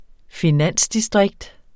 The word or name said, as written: finansdistrikt